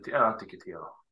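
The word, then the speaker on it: Antikythera: Så det är antika världen, och sen så har jag sk då skrivit en uppsats som handlar om antikyter och mekanismer. – Så uttalas det. – Antikytera.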